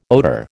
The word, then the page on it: odour: American English